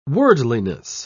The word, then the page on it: worldliness: British English